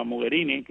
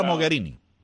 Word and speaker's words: Mogherini: Yo quería hacer notar que a partir que la señora, la alta representante para la Unión Europea, la señora Mogherini, quizás no estoy pronunciando bien su nombre. – Sí, es así, está bien. Federica Mogherini.